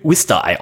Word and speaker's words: Worcester: Also, geschrieben wird Worcester, aber ich glaube, es wird Worcester ausgesprochen oder sowas.